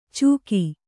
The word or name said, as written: ಚುಕಿ